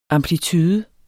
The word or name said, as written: amplitude